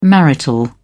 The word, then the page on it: marital: British English